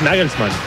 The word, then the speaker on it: Nagelsmann: Es Nagelsmann.